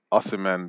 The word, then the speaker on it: Osimhen: I say Osimhen because that's what even Nigerians say that.